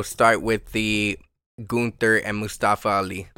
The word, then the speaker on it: Mustafa: Mustafa. – Gunther versus Mustafa Ali for the Intercontinental Championship.